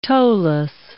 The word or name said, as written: toeless